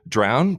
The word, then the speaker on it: Drown: No, it's like, you know, good and connections, right? Good connect. – It's that easy? But try to take my last name and it's either Drown, Druin, Brown, Brown, Dune.